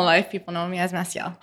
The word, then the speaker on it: Massiel: So Massiel is how you pronounce the name, right? – Yes, okay, Massiel. Good.